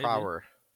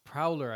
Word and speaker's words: Prower: It's Miles maybe. Prower. Prower. – It's Prowler, I thought. – I was wrong when I said it, when I pronounced it just now. – It's Prower? – Oh, you stutter nest. – Yep. – Is it Prower? – Oh, sure shit.